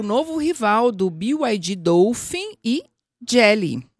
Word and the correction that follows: Geely: Jilly, ou melhor. É difícil de pronunciar, né? Mas a gente vai aprender a falar. Jilly.